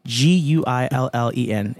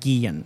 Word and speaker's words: Guillen: HARVEY GUILLEN. It's pronounced Guillen. Like guillotine.